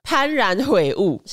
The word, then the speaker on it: 幡然悔悟: 那下一个刊物七十七集里面，你讲一个幡然悔悟，其实正确的读音是幡哦，所以是念幡然悔悟。